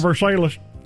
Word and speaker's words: Versailles: She gets their Malibu Cove home and their home in Versailles, Kentucky. Is that the way you pronounce it in Kentucky? Is it pronounced differently there? – Kentucky, it's got to be Versailles, doesn't it? – Versailles.